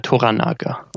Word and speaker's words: Toranaga: Und die Lords verlangt haben, oder dieser Rat der Lords, du kannst ihn besser aussprechen. Wie spricht man ihn aus? – Toranaga.